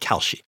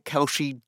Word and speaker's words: Kalshi: Her name is Luana Lopez Lara, and the exchange is called Kalshi.